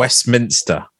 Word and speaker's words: Westminster: Westminster. – Sorry. I'm adding a syllable. – You don't need to put the innister. – Yes. – Because okay. It's too much like sinister and they don't.